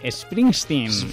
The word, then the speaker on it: Springsteen: Springsteen. Springsteen, ¿vale? Con E al principio.